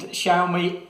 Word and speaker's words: Xiaomi: And Xiaomi is the way to pronounce this.